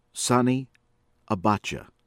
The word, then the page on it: Sani Abacha: American English; from Nigeria